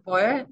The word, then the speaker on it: Beuel: It's @Judith Boyle. My name's actually pronounced a bit different, but it's spelled like Judith.